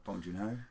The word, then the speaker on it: Bong Joon-ho: Bong Joon-ho. – That's the one, Bong Joon-ho, because I'd forgotten the director's name.